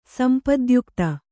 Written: ಸಂಪದ್ಯುಕ್ತೆ

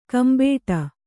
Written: ಕೆಂಬಟ್ಟೆ

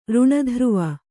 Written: ಋಣಧ್ರುವ

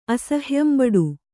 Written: ಅಸಹ್ಯಂಬಡು

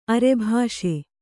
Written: ಅರ್ಬಿಸು

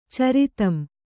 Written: ಚರಿತಂ

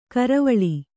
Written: ಕರವಾಲ

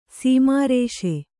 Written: ಸ್ಮರಿಸು

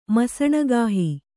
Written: ಮಸಣಗಾಹಿ

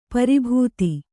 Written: ಪರಿಭೇದ

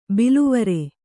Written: ಬಿಲವರ

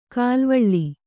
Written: ಕಲುವಳೆ

